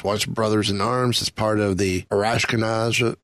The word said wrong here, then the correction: Arashikage: God, I never say this right. – Yeah, it's a tough one. – Arashikage clan. There we go.